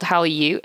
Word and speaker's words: Hallyu: Why is it called How are you? It's spelled H-A-L-L-Y-U.